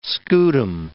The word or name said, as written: scutum